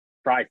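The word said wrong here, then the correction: Fyre: Fest. – Was that bad? – Fire Fest.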